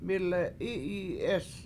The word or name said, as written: mI7le7I7i7EEs